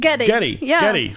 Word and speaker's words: Geddy: So his real name is like Gary. And yeah. But his mom pronounced it Geddy. – So he went by Geddy.